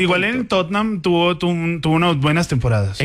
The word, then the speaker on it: Tottenham: Tottenham. Tottenham. Son dos sílabas.